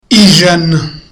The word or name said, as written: ijana